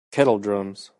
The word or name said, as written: kettle-drums